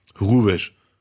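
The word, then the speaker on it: Hrubesch: Man weiß nicht, spricht man das H aus oder nicht? – Ja, ich weiß. Nee, natürlich spricht man es aus. Warum sollte er Französisch sein? – Horst Rubesch.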